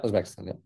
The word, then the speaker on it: Uzbekistan: Uzbekistan, did I say that correctly? – Yeah, Uzbekistan, yeah.